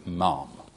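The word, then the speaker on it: Ma'am: You can go on the website, that it has to be Mum. That's the correct pronunciation.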